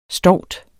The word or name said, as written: stout